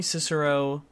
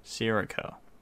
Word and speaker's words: Sirico: Tony Cicero, or Sirico? How did I forget? I think it's Cicero. But I also don't think it is. Tony Sirico. Pronounce name. Sirico. Tony Sirico, okay.